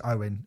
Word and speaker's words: Eoghan: So Eoghan is Owen, it's pronounced, but I'm going to call him Eoghan.